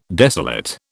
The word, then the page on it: desolate: British English